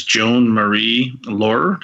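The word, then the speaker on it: Joan Marie Laurer: China's real name was listed as Joan Marie Lohrer. Did I say that right? – Yes, you did.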